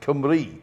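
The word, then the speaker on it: Cymru: It's spelt Qumru, but it's pronounced Qumri. Yeah. So for some reason, the U is a Y and the Y is a U.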